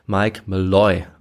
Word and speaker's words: Malloy: Ich bin einfach davon ausgegangen, dass er Malloy heißt, ohne weiter darüber nachzudenken. Er heißt aber Mike Malloy, also auf die letzte Silbe kommt die Betonung.